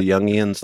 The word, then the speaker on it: Jungians: The Jungians, like Carl Jung? – Yes. – Oh. – That interpretation. – The Jungians. – And, yeah. – The Jungians.